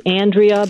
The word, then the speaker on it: Andrea: And by the way, am I saying your name correctly? It's Andrea, not Andrea? – Yes, it's Andrea, but I'll come to Andrea Rayla.